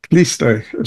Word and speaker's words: Khlysty: So one of the mystical groups that I was pretty much unaware of, except for that vague thing I was talking about, it's spelled K-H-L-Y-S-T-Y. Kliste is what they call them.